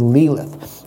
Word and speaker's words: Lilith: Sometimes it's just called Lilith, but I think it's pronounced actually Lilith.